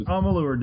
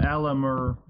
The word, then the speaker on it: Amalur: Alamar. – Amalur. – It's Amalur, dude. – Amalur? – A-M-A-L-U-R. Yeah, that's how it's spelled. – Amalur. Oh. – No, not Alamur.